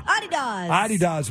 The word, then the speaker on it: Adidas: Adidas, which is how they say it in Europe.